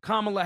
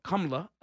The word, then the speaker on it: Kamala: Now you're not paying attention to the person that's actually racist, to the point where Kamala Harris, or Kamala, I'll pronounce her name properly, her Indian name, she'll say months ago on the stage, Joe Biden, you're racist.